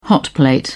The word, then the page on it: hotplate: British English